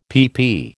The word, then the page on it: pp: American English